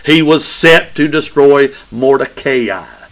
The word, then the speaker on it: Mordecai: That's the way you actually pronounce it. And when I actually learn that I can pronounce something right, I try to use it because I'm not very good at it. I used to say Mordecai. It ain't Mordecai, it's Mordecai. But you can say Mordecai if you want to be wrong.